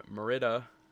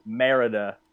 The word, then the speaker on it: Merida: The film tells the story of a princess named Merida. Did I say that wrong again? – He said Merida. – What is it? – Merida. – Merida. – There you go.